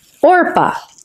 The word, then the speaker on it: Orpah: Orpah.